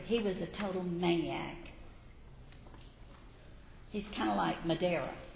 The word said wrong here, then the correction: Maduro: Is that how you say it? Maduro.